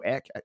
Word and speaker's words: Ek: And it's got the guy, I think it's Daniel Ek.